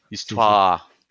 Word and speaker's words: Histoire: Histoire, si se dice así como Histoire, o Estoire. – Histoire, ¿no? Porque es, es, es, Histoire.